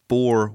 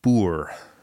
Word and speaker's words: Boer: I called it the Boer War. – Okay. – Right. – And it's actually pronounced Boer.